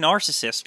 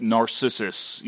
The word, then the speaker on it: Narcissus: He's not even saying Narcissist correctly. – It's Narcissus. You know, like it's a mythical...